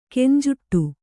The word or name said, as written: ಗುಂಜಾಡು